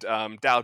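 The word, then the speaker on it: Tao: So I found this article by someone named Dow Dow Holmes. I hope I'm pronouncing that right. It's T-A-O, as in the Dow, twice.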